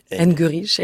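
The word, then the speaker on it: Argerich: aucun acteur sera Martha Eng, je ne prononce jamais bien son nom, la grande pianiste, Martha Engerich.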